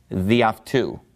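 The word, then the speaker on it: diaftu (δι' αὐτοῦ): So it's just pronounced diaftu, meaning by him. So all things by him, diaftu.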